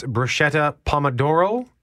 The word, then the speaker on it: bruschetta pomodoro: It's bruschetta pomodoro. Did I say that correctly? – Yes. That's all fresh that we make,